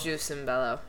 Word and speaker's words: Jus in Bello: Wait, it showed the pronunciation. Yes, it is a long O. – It is Juice and Bello.